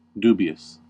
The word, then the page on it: dubious: American English